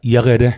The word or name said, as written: Yehede